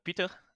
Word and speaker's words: Peter: I'm pronouncing it in Dutch. I'm from the Netherlands, so Peter Pabst. – Awesome. And I'm so Americanizing it, Peter. – Yeah. And apologies for that. – Yeah, you have the beer with my last name as well. I'm always interested in how you pronounce it actually in English. – Pabst. – Pabst. Okay. Yeah. So I'm Peter Pabst in your language.